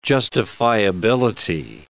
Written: justifiability

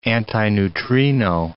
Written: antineutrino

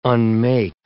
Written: unmake

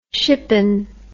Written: shippen

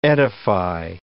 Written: edify

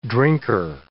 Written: drinker

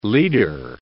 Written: leader

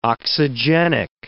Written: oxygenic

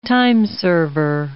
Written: timeserver

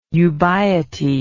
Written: ubiety

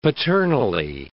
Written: paternally

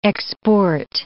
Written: export

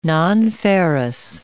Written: nonferrous

